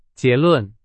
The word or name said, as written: 结论